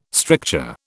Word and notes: stricture: British English

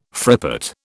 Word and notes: frippet: British English

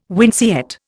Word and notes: winceyette: Indian

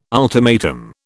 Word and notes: ultimatum: British English

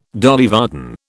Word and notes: Dolly Varden: British English

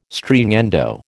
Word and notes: Stringendo: American English

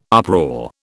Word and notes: uproar: British English

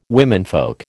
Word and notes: womenfolk: American English

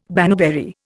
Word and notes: baneberry: Indian

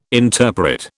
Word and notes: interpret: British English

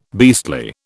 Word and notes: beastly: British English

